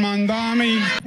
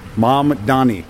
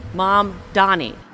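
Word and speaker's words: Mamdani: All right, so the pronunciation of the mayor-elect's name is what? – Mamdami. – No. – Mamdami. – Mom Donnie. – Mom Donnie.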